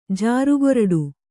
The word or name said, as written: ಜಾರುಗೊರಡು